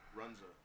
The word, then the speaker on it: Runza: If you work for ESPN, you pronounce it wrong. We're reviewing Runza.